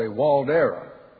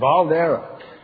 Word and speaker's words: Waldera: We have a horse named, all the announcers at the horse show say Waldera, because it starts with a W. But it's a German name, and it's Waldera. You put the V, they pronounce the W with a V.